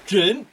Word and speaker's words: Trịnh: It's pronounced Trin.